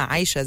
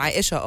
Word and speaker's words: عائشة: فهل قصدك ان انتي كنتوا او انتوا كنتوا بتنطقوا اسمها عايشة زي الاغنية؟ فهي عايشة ده الناس كلها بتقول عايشة، هي بيبقى عائشة الاسم الحقيقي بس الناس بتقولها عايشة،